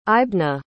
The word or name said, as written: aibhne